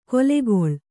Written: ಗೊಲೆಗೊಳ್

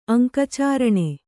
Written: ಅಂಕಚಾರಣೆ

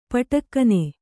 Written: ಪಟಕ್ಕನೆ

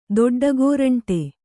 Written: ದೊಡ್ಡಗೋರಂಟಿ

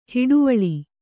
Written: ಹೊಡೆವಲೆ

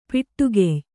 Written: ಪೊಟ್ಟುಗೆಯ್

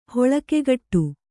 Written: ಹೊಳಕೆಗಟ್ಟು

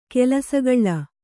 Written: ಕಲಸುಗೂಳು

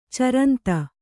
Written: ಚರಂತ

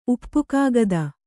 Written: ಉಪ್ಪುಕಾಗದ

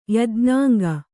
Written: ಯಜ್ಞಾಂಗ

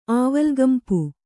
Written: ಆವಲ್ಗಂಪು

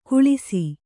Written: ಕುಳಿಸಿ

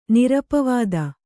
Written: ನಿರಪವಾದ

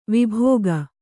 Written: ವೈಭೋಗ

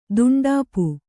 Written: ದುಂಡಾಪು

